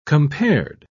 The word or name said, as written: compared